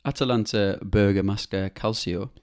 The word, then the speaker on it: Atalanta Bergamasca Calcio: Atalanta Bergamasca Calcio.